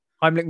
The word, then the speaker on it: Heimlich: Is it Heimlich? – Heimlich maneuver, yeah. – I pronounced it correctly this time.